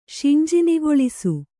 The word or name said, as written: ಶಿಂಜಿನಿಗೊಳಿಸು